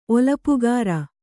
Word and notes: ಒಲಪುಗಾರ: olapugāra

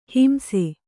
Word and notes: ಹಿಂಸೆ: himse

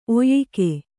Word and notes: ಒಯಕ್: oyak

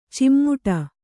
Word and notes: ಚಿಮ್ಮುಟ: cimmuṭa

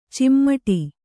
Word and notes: ಚಿಮ್ಮಟಿ: cimmaṭi

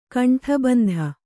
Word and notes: ಕಂಠಬಂಧ: kannada

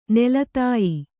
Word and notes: ನೆಲತಾಯಿ: nela tāyi

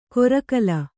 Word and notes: ಕೊರಕಲ್: korakal

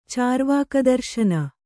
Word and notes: ಚಾರ್ವಾಕದರ್ಶನ: cārvāka darśana

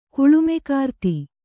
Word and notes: ಕುಳುಮೆಕಾರ್ತಿ: Kannada

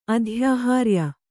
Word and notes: ಅಧ್ಯಾಹಾರ್ಯ: adhyāhārya

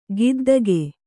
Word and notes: ಗುದ್ದಿಕೆ: Kannada

